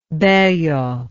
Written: Bello

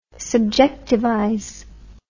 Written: subjectivize

